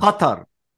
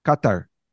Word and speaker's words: Qatar: You want the real pronunciation? – Yeah. – Qatar. – Qatar.